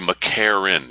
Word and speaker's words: McEachern: You know, there are a lot of names like that, but it's spelled McEachern, E-A-C-H-E-R-N. Even at the end, we say McArron. It's like there's nothing between the R and the N.